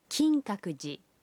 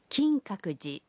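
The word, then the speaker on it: کنگ ککو جی (金閣寺): مندر کے نام کا تلفظ ہے کنگ ککو جی. کنگ ککو جی.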